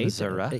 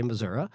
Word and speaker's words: Missouri: Missouri. – Yeah. – Yes. – Rural Missourians tend to say Missouri.